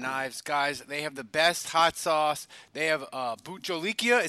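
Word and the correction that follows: Bhut jolokia: Is that right, Andrew? Did I say it right? – Bucholiquia. – Jalokia.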